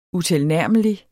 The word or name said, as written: utilnærmelig